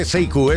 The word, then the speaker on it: Sekou: que sepas que se escribe Sekou, pero se dice Seiku, eso que quede bien claro, ¿eh?